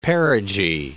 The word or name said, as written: perigee